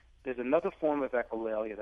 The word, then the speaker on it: echolalia: Talk a little bit about what echolalia is. Am I pronouncing that right? – Echolalia. – Echolalia, yes. Okay.